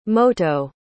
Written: moto

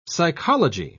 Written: psychology

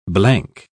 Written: blank

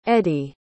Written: edee

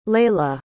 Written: layla

